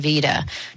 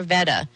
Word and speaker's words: Veda: I am CJ Liu, and today we have Philip Goldberg, who's the author of American Vita. Did I pronounce that right, Philip? Is it Vita or Veda? – Veda. – Veda. None of them. Veda. Okay, like Darth Vader.